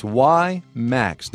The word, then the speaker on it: WiMAX: Today is the 7th of May, and just so we're clear here, it's WiMAX. W-I-M-A-X is how you spell it.